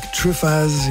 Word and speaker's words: Truffaz: This is Eric Truffaz. He pronounces the Z at the end, the Z.